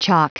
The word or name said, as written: chalk